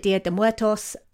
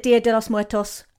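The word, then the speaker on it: Día de los Muertos: Obviously, you can also call it Dia de Muertos, but she confirmed that Dia de los Muertos was correct.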